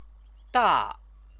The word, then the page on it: da: Chinese